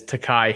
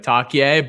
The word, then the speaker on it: Takai: Yeah, I think pronunciation for some of the newcomers, I know just one of them off the top of my head is Takai Heath. And I think we had mispronounced it earlier this season, maybe like Takye, but Takai Heath,